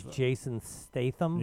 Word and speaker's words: Jason Statham: It stars Jason Statham.